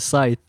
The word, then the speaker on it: Scythe: Yeah, it's Scythe. – I didn't know that. – The C is silent.